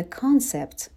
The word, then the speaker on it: concept: The concept، به تلفظش دقت کنین. تلفظش صدای آ هستش.